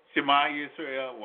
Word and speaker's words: Shema Yisrael: All you have to do is just say Shema Israel, Yahweh, Eloheinu, Yahweh, Echad. Shema Yisrael, well, I said it wrong.